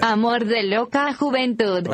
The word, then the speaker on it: Amor de loca juventud: Amor de loca juventud.